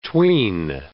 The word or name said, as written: tween